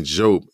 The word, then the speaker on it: Diop: So I didn't know that she had this Senegalese background, and her story, the book, when she jumps in talking about the pronunciation of her name, it being Jope as opposed to Diop.